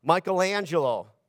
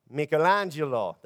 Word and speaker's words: Michelangelo: And one of those people is, I won't say it like I'm supposed to, but Michelangelo, right? But she would call it, how would you say it? Michelangelo. There you go. Michelangelo.